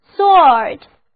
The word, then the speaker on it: salt: Salt, S-A-L-T, A 字母发 O 的长音，单词就读作 salt。